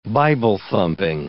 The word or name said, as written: Bible-thumping